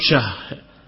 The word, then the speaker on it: Syah: Yakni dia dengan hak, apakah dilanjutkan membacanya ataupun diputus, tetap Syah. Enam. Yakni yuqalu lahu Abu Syahin faqala, begitu. Atau berhenti, yuqalu lahu Abu Syah, tetap dengan hak.